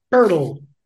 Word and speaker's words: Poeltl: Jakob Poeltl. – Poeltl. – Spelled like P-O, yeah, spelled P-O-E-L-T-L.